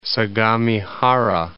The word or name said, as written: Sagamihara